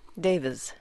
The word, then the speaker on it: Davies: That's Davies. I think over here the tendency is to say Davies. Davies is not quite right. So think of it with a Z at the end. Z. – Davies.